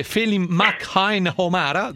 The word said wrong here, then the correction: Felim McNamara: giusto? – Felim McNamara.